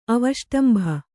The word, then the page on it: ಅವಷ್ಟಂಭ: avaṣṭambha